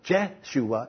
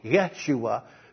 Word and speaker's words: Yeshua: And that pronunciation is not Jeshua, it is Yeshua.